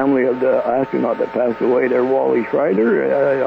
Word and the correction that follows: Schirra: I hope I said his name right. – Wally Schirra. – Schirra, that.